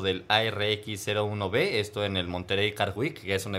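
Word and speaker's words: Monterey: Sí, el Monterrey Car Week, que no está mal pronunciado, es Monterrey en Estados Monterrey.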